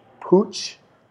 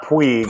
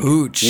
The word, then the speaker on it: Puig: And the voice memo says, hey, ETR, my name is Ricky Pooch, not Puig. – Pooch.